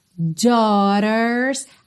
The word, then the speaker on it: daughters: Daughters, atenção à pronúncia dessa palavra, porque se escreve D-A-U-G-H-T-E-R-S, então às vezes parece que vai ser uma pronúncia bem diferente, mas esse A-U aí a gente vai falar como se fosse um O, dó, dó, ok? O G-H não vai ter som nenhum,